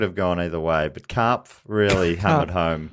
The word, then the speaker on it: Karpf: Karpf. – Karpf. – Right. How do you spell that? – Lorna Karpf. K-A-R-P-F.